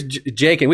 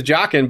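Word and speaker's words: Jaqen: So here's Jakin. We call him Jakin. I know the name is Jockin, but we called him Jakin before the TV show clarified the pronunciation.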